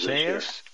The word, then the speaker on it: Isaias: It's Hurricane Isaiah, but it's got an S on the end of it. It's not just Isaiah. – Yeah, right. It's when I heard him pronounce it, it's a different pronunciation. They got all these weird names. – Isaias? Isaias? I-S-A-I-A with an S on it. You figure it out.